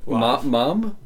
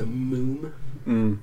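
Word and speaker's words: Múm: M-U with an apostrophe atop it. So it's little... Umlaut. – And then an M. So Moom is how you pronounce it. – Everybody says Mom, I bet.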